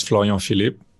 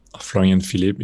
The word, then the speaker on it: Florian Philipp: My name is Florian Philipp. Florian Philipp, if you want to say it with the English accent. It's very hard for me to say my name with an accent.